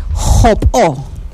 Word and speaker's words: jopó: Bueno, el sombrero en Yucatán se dice jopó. – Jopó. – Jopó. Que se escribe de esta manera. Empezamos con la letra J.